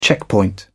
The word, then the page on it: checkpoint: British English